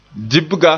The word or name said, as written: dibga